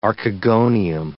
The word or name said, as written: archegonium